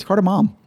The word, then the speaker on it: cardamom: Is that how it's spelled? Mom? Isn't there an N at the end? – It's cardamom. – Oh, for Christ's sakes. Is it really? – Yeah. – Wow. – It's got mom in it.